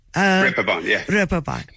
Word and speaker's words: Reeperbahn: Reeperbahn.